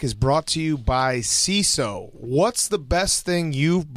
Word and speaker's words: Seeso: CISO, it's spelled S-E-E-S-O, is the new ad-free streaming service bringing you hilarious original series, weeks of stand-up specials, and more.